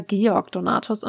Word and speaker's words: Georg Donatus: Aber jetzt, wo ich es laut ausgesprochen, jetzt, wo ich es laut ausgesprochen habe, glaube ich, ist es eher Georg Donatus und nicht George.